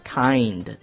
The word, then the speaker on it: kind: 我跟你讲，大家为什么读不准这个呢？基本上读成了看的。 – 对。 – 看的。 – 看的。 – 它实际上有一个双元音嘛，所以你要润滑一下 kind。